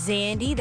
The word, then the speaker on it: Xandi: Yeah, so again, my name is Zandy. That is spelled X-A-N-D-I.